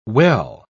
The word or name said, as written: well